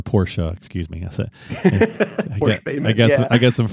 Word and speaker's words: Porsche: It's not Porsche. It's Porsche.